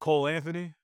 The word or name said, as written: cole ANTHONY